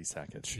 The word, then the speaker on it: Sakic: But his pronunciation, the true pronunciation of his name would be Sakic.